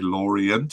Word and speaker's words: Lorient: I don't know why I'm saying everything in a French pronunciation, so maybe I should say Lorient so that it doesn't sound like I'm pretending to be French. I do speak some French, but that's besides the point.